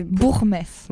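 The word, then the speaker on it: Buchmesse: Alors, je ne sais pas le prononcer, c'est Bourgmesse. – Voilà, c'est ça exactement.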